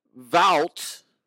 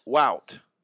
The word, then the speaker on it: Wout: Vout is his first name. What? Vout. Wout. Nope. Vout. Wout. It's spelled W O U T, right? I don't think most people are going to be surprised that it's Vout.